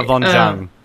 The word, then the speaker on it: Yvonne Jung: You got, how do you say her name? I've never been good at it. – Yvonne Jung. – There you go.